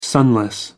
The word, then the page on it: sunless: American English